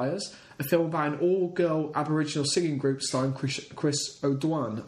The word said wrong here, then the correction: O'Dowd: I think that's how you pronounce it. O'Dowd. Is O'Dowd.